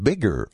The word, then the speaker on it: BIGR: The founder of Bigger Audio, which is B-I-G-R, pronounced Bigger Audio, is Rich, Big Rich Brocky.